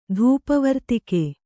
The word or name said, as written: ಧೂಪವರ್ತಿಕೆ